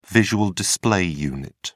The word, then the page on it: visual display unit: British English